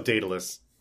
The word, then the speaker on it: Daedalus: Is that how you pronounce that? Daedalus. – It's Daedalus.